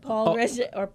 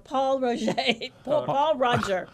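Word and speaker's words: Pol Roger: Paul Roger. – Paul Roger. Yeah. Right. – Now, I can say it like Edouard. Paul Roger. Paul Roger.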